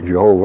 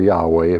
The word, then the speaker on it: Yahweh: We have here Jehovah or Yahweh, if you prefer that pronunciation.